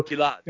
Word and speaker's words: Gilad: So, Gilad.